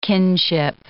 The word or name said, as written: kinship